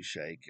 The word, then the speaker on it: sheik: It's like the word sheik always pronounced wrong. It's supposed to be shake.